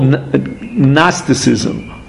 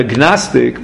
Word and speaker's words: Gnosticism: Now, in English, again, I'm not sure how to pronounce this, because there's a G at the beginning of the word, but I don't think you pronounce the G. It's called Gnosticism. Right, a Gnostic,